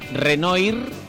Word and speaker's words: Renoir: Se escribe Renoir, como los cines, y se pronuncia Renoir.